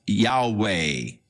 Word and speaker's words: Yahweh: So the general consensus of opinion is that the name was to be pronounced Yahweh. But somewhere along the line, the pronunciation of Jehovah came along, or Yehovah, and it has become more popular. But Yahweh is probably the correct pronunciation, though we do not know for sure.